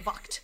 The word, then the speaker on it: Vogt: It's VOGT. Vocht. VOGT hyphen Roberts.